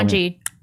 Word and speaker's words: Ketanji: Ketanji Brown. – Thank you for making it the proper pronunciation. L-E-L or L-E-B.